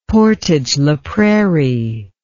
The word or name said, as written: portage la prairie